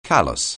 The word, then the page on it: callus: anglais